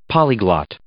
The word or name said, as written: polyglot